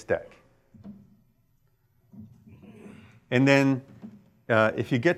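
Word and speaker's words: deque: And in fact, it's a double-ended queue, which we pronounce deck.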